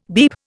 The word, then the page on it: beep: Indian